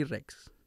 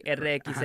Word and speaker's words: Rexx: R-E-X-X. Sí, la gente no sabe cómo se pronuncia, pero es Legally Rex. – Ah, ok, Rex. O sea, no es de que Legally RXX. – Ah, no, Legally Rex.